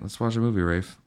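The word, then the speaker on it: Ralph: It's actually pronounced Rafe.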